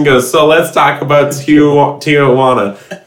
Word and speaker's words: Tijuana: It's pronounced Tijuana.